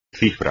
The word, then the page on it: cifra: espagnol